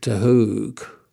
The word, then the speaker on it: de Hoog: So I was in Ireland a few months later and I went to see this friend of mine, Mick de Hoog, as we call him. It's a Dutch name. I think it's properly pronounced De Hoog, D-E-H-O-O-G.